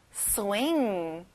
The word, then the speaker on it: swing: 그네 흔들리다, swing. 잘했어요. 끝에 G 발음은 안 나요.